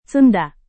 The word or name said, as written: sunda